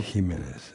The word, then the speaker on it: Jimenez: Jimenez. – Evet. Jimenez diye telaffuz edelim o zaman.